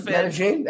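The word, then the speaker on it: Medellín: It's Medellin, right? Medellin Mets fan. – Medellin, that's good.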